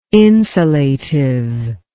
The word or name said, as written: insulative